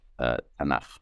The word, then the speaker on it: Tanakh: It's the acronym of Torah, Nevi'im, Ketuvim, the law, the prophets, the writings. And when it's acronized, so we pronounce it Tanakh, Bible.